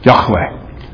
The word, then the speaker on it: Yahweh: Literally, the word is Yahweh. Yahweh. That's how you say it in Hebrew. It's sometimes transliterated into English as Jehovah.